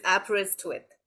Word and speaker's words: Aberystwyth: So I break it down to three parts. So it's abs, as in abs that we all yearn for, wrist, and width. So it's Aberystwyth, and for short form is ABBA.